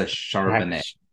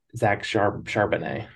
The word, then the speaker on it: Charbonnet: I like how you pronounce his name Chardonnay, because of course I like Chardonnay myself. But it is with a B. It's a Charbonnet. – Charbonnet.